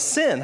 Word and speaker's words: Sin: I said, Sin.